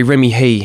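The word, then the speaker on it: Hii: Probably my favourite storyline so far has been the stuff with Hudson, who's played by Remy High. Sorry, Remy He.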